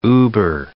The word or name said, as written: über-